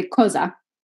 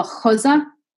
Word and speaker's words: cosa: to say what you say cosa with a hard c but the tuscan dialect turns all the c's into this kind of h sound so instead of saying cosa they would go cosa and for like the first few days, I would just look at them like, what are you saying?